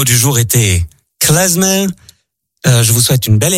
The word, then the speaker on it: klezmer: Et il faut aussi, d'ailleurs, préciser, j'entends parfois des gens qui prononcent klezmer parce qu'ils assimilent le Z au Z allemand. Or, bien évidemment, c'est de l'hébreu, zemer.